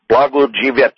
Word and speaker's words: Blagojevich: That's how you pronounce it, Blagojevich.